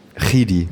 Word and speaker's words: Chidi: Kidi wird es ja ausgesprochen. – Wie? – Chidi. – Chidi, genau.